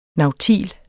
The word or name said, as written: NAUTILEN